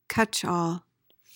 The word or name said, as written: catchall